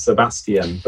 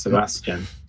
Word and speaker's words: Sebastian: In the UK, we give it four syllables, Sebastian. But over there, I think you only give it three, right? It's Sebastian.